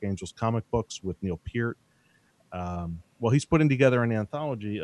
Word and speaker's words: Peart: Is that how you pronounce Neil? – Peart. – Peart? – Peart. – Oh, my God. I've been saying Peart my whole life.